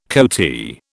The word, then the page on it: coatee: British English